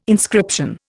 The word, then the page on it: inscription: Indian